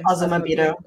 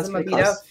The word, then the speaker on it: Azumabito: Azumabito, is that how you say it? – Azumabito. Yeah, Azumabito.